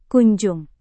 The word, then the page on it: kunjung: indonesian